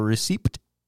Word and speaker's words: receipt: Well, he's reading it phonetically. Receipt. – Yeah. Yeah, what's wrong with receipt? – Ah, so, the English language. Adding letters you don't need.